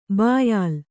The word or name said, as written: ಬಯಲು